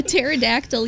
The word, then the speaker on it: pterodactyl: Why can't you hear a pterodactyl use the bathroom? – I know this one. – I know you do. Its pee is silent.